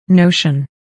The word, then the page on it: notion: nóuʃən; ノウション